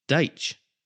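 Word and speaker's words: Dyche: Deitch. That's how he pronounces it himself. There we go.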